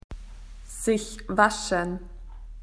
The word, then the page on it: to wash yourself: Australian English